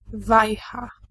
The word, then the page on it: wajcha: /ˈvajxa/